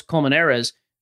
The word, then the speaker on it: Colmenares: So like Carlos Colmenares, your pronunciation, I think that's actually spot on, by the way.